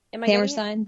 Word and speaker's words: Hammerstein: Amerstein? – The Hammer. Hammerstein Ballroom? – All right, good, I got it.